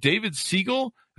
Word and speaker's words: David Siegel: We have today on the show, I believe, David Siegel.